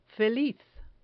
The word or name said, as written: feliz